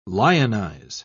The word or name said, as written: lionize